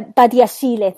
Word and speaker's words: Badiashile: Badiashile, is that how you say it? – Badiashile, I think. – Yeah, Badiashile.